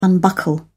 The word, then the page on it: unbuckle: British English